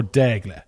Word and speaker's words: Daigle: And John Daigle, or Daigle, the French pronunciation.